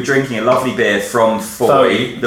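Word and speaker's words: Fowey: He pronounced it Foy. And it's pronounced Foy, like Claire Foy, I've been told.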